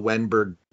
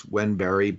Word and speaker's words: Wennberg: and only and, and uh, many Russian players, but with the Swedish players, um, you know, in Sweden, that Berg is, you know, Wennberg, Broberg. It's it's Wenberry, Broberry. From listening are listening to uh, Swedish hockey games, that's how all the announcers pronounce all these players.